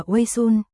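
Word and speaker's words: wei sun (微醺): Ngà ngà say là một từ ngữ tiếng Hán, đọc là wei sun, ý chỉ hơi có men say, cảm giác có chút lâng lâng.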